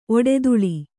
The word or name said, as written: ಒಡ್ತಲಿ